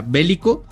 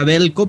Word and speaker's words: Veljko: Bueno, dice Gustavo que en su sapiencia de serbio, y yo le creo, se pronuncia Bélico. A mí me decían que era Belko, pero bueno, Veljko Paunovic es el nuevo técnico de Chivas.